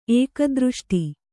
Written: ಏಕದೃಷ್ಟಿ

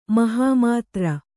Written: ಮಹಾಮಾತ್ರ

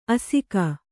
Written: ಅಸಗ

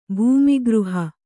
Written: ಭೂಮಿಗೃಹ

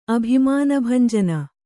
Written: ಅಭಿಮಾನಭಂಜನ

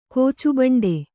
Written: ಕೋಚುಬಂಡೆ

